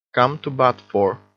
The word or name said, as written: come to bat for